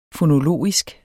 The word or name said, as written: fonologisk